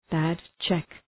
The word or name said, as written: bad check